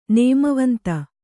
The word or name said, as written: ನೇಮವಂತ